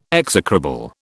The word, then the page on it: execrable: British English